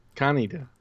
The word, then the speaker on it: Kaneda: Kaneda. That's how they say it.